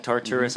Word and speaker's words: Tartarus: Is that how you say that? Tartarus? – Mm-hmm. – I always say that wrong.